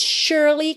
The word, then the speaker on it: surely: It's up to you, it surely can be done. Essa palavra que se escreve S-U-R-E-L-Y se pronuncia surely, surely, ok?